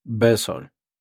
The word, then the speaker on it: vessel: La pronunciación fonética es V-E-S-O-L. Besol.